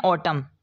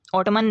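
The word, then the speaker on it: autumn: इसके बाद silent N, इसको पढ़ते हैं ऑटम, ऑटमन नहीं पढ़ना ऑटम।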